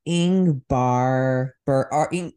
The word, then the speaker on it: Ingmar Bergman: But it has I think I'm saying that name wrong. – Yeah, probably. I don't know, though. Ingrid? Ingvar? – I think it's Ingmar Bergman.